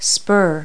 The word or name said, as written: spur